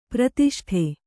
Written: ಪರಿತುಷ್ಟೆ

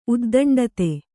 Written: ಉದ್ದಂಡತೆ

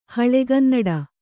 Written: ಹಳೆಗನ್ನಡ

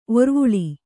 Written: ಒರ್ವುಳಿ

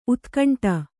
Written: ಊದುಗಂಡಿ

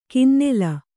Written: ಕಿನ್ನೆಲ

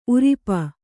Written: ಊರಪ್ಪ

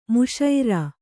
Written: ಮಿಶ್ರ

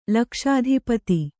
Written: ಲಕ್ಷಾಧಿಪತಿ

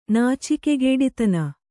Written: ನಾಚಿಕೆಗೇಡಿತನ

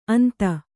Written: ಅಂತೆ

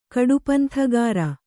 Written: ಕಡುಪಂಥಗಾರ

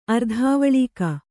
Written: ಅರ್ಧಾವಲೀಕ

